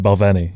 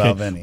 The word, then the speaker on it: Balvenie: Yeah, and you know, being 55 years with the company, you're just like, okay, I'll go with Balvenie. – Balvenie. Yeah. Okay. Balvenie. But if you think about it, that seems like it has the right accent in addition to the emphasis being on that particular syllable.